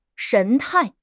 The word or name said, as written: 神态